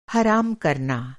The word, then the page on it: हराम: حرام